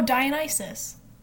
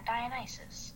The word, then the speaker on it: Dionysus: Oh, Dionysus. – Yeah, Dionysus. Okay, that's how you pronounce it.